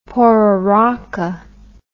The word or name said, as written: pororoca